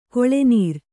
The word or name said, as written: ಗುಳ್ಳೆನರಿ